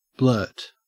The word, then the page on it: blurt: Australian English